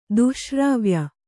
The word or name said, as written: ದುಶ್ಶ್ರಾವ್ಯ